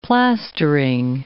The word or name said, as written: plastering